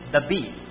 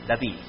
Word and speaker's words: David: And the pronunciation in Hebrew is David. That is my name, David, not David. So I got the right pronunciation of my name. Not like David over there.